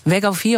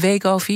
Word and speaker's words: Wegovy: Maar ook Wegovy, of is het Wegovy? Ik weet eigenlijk nooit hoe ik het moet uitspreken. Mag allebei.